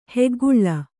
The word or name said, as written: ಹೆಗ್ಗುಳ್ಳ